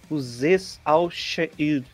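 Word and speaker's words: Azeez Al-Shaair: Mark Nizotia e Aziz Al-Shair, Falei certo, será? Bom, ele não vai estar tá aqui para me corrigir, né? É Uzes, né? Uzes Al-Sha'id. Tá aqui a pronúncia, o guia de pronúncia tem.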